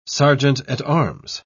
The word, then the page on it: serjeant-at-arms: British English